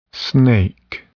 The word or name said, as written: snake